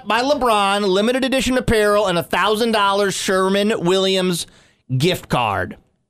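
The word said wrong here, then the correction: Sherwin-Williams: Oh, it's not Sherman. It's Sherwin Williams.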